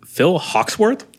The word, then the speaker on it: Phil Hawksworth: And then we've got also, as our guest today, we have Phil Hawksworth.